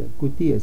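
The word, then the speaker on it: Cutié: Solamente pongan Alberto Coutier, así tal como se pronuncia, Coutier, C-U-T-I-E, Alberto Coutier.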